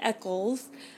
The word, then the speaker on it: Eccles: Um, then there's Krumm, voiced by David Eccles. Um, I don't think that I don't know if that's how you pronounce that. It's E C C L E S. Yeah, that sounds right.